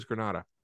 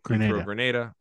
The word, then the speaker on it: Grenada: You finally pronounced it the right way. – Oh, it's Grenada? – It's Grenada. – Oh, I thought it was Grenada. Anyway. – No, Grenada.